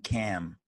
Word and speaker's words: calm: I just learned that in Ireland, you pronounce calm, cam.